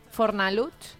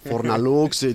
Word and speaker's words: Fornalutx: Te t'anava a dir, Fornalutx no deu ser fàcil de pronunciar ni pels castellano parlants de la península, no? Fornalutx. – Deu dir Fornalutx. – Clar, perquè entre aquesta X final... – Sí, és veritat. Fornalux, diuen... Sí.